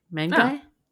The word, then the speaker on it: Mengui: It's spelled M-E-N-G-U-I. Mengui? Literally. Yeah. Phonetically. Yeah.